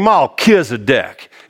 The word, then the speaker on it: Melchizedek: Right? Melchizedek. That's how you say it in the South. King Melchizedek.